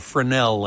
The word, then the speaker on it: Fresnel: But if you're looking straight on, They had to do a special coating on this screen called a Fresnel lens, spelled F-R-E-S-N-E-L, but the S is not pronounced.